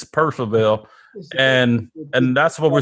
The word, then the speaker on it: Purcellville: per- it, yeah, it's it's spelled Purcellville, but that those L's in the middle, people just it's Percival.